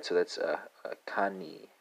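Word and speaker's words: kanë: And that's uh, spelled uh, Kane, right? Uh, K A uh, N E, right? So kind of similar to cane sugar,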